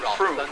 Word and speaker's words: Frome: The possibilities for misunderstanding are multiplied by the fact that it is spelt F-R-O-M-E. So to the uninitiated, this is quite clearly pronounced Frome, as in Rome, rather than Froome, as in Boom.